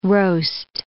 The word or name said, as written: roast